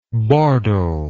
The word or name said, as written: bordel